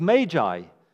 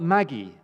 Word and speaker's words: magi: The literal word in the Greek is magi. Not Maggie, not pronounced Maggie, as a friend of mine thought they were called. It wasn't that Maggie came to visit Jesus, it's the magi.